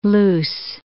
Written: luce

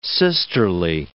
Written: sisterly

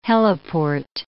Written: heliport

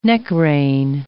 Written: neck-rein

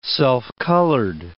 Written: self-colored

